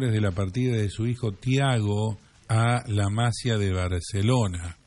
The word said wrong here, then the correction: Masía: La Masía se dice. Sí, tenés razón, tiene un acento en la I.